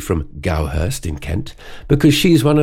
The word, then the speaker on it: Goudhurst: so I thought it was, is it Goudhurst or Goudhurst? So I looked it up, and it's Gowhurst, with D in the middle of the name, silent.